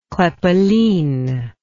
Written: kraepelin

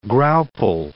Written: graupel

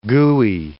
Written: gooey

